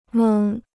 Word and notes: 蒙: méng

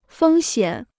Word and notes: 风险: fēng xiǎn

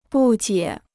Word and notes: 不解: bù jiě

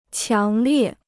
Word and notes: 强烈: qiáng liè

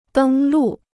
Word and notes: 登陆: dēng lù

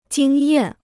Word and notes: 经验: jīng yàn

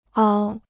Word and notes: 凹: āo

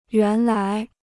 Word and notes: 原来: yuán lái

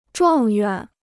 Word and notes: 状元: zhuàng yuán

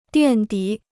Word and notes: 垫底: diàn dǐ

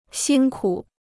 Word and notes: 辛苦: xīn kǔ

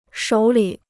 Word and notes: 手里: shǒu lǐ

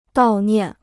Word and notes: 悼念: dào niàn

